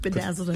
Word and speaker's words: Bedazzled: We got a vendor who can pedazzle your hat. – Can do what? – Am I saying that right? – Bedazzled is the word, yes.